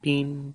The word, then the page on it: pin: Lwaitel